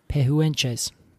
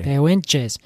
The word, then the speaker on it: Pehuenche: Spell it for me. – Pehuenches. Pehuenches. – Pehuenche. – Pehuenches. – Is what I would say. – I think that's probably a lot better than I said it.